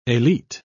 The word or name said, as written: elite